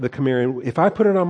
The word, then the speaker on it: Cimmerian: I think it's a hard C, Cimmerian people.